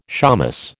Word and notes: shamus: American English

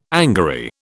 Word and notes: angary: British English

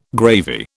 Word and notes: gravy: British English

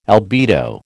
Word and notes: albedo: American English

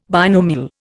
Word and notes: binomial: Indian

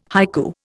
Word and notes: haiku: Indian

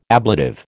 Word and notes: ablative: American English

